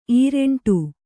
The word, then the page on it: ಇರಿಂಟು: iriṇṭu